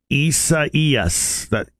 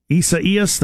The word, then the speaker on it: Isaias: It's pronounced Isaias. Isaias, the hurricane? Okay. Starts with an I, but it's E.